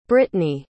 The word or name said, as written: brittnay